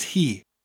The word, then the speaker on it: hi: When you're beginning to learn Welsh, the word for she in Welsh is he. Honestly, the word for she in Welsh is pronounced he. He. But spelled H-I.